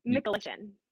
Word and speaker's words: Michalyshen: Close. Very close. – Okay. – Michalyshen.